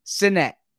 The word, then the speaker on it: Sinnett: And so the Eagles media guide here, if you can see, has the pronunciation, and it's Sinnett, Reed Sinnett. So it's not Sinnett, it's Sinnett. – Sinnett. – But they emphasize the S-I-N.